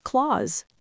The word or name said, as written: clause